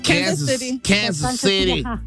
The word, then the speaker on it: Kansas City: ¿Kansas City o cómo se dice? – Kansas City. – Kansas City.